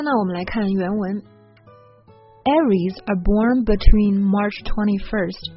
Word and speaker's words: Aries: Aries。那它的这个发音呢稍微奇怪一点，就是这个 A 是吧，它发的是 red 那个小 E 的那个音，而不是梅花 I，大家要注意了。